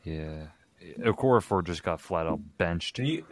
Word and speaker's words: Okorafor: Steelers offensive lineman Chukwuma, I don't even know how to pronounce his name, Okorafor. It's Okorafor who I'm talking about.